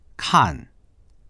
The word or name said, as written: kan4